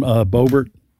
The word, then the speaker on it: Boebert: Lauren Boebert.